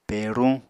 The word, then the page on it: peruñ: Apatani